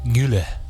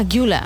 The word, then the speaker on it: Güler: Creo que Arda es Arda Güler. – Ah, Güler.